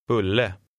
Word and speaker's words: Bulle: The U in number 17 is followed by a double consonant, so it makes a shorter sound. Listen and repeat. – Bulle.